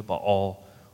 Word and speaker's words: Baal: I have kept for myself 7,000 men who have not bowed the knee to Baal or Baal, depending on how you pronounce it.